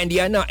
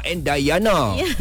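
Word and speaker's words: Andyana: Epi, bukan Andiana, Andayana. – Sebab ejaannya D-Y-A-N-A. – Oh, macam saya ni lah, Ipai. E-P-Y, Ipai, Syafiq.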